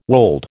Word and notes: wold: American English

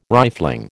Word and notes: rifling: American English

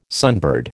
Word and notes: sunbird: American English

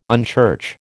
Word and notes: unchurch: American English

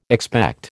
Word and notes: expect: American English

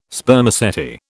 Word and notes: Spermaceti: British English

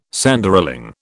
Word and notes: Sanderling: British English

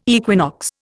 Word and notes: equinox: Indian

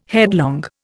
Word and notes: headlong: Indian

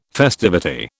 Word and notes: festivity: British English

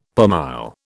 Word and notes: per mille: British English

